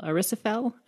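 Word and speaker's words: Aziraphale: A plot of it is essentially the fight for Earth at Armageddon between the demon Crowley and the angel Aristophel. I can't pronounce his name. That's probably wrong. But anyway. – No, you got it right there.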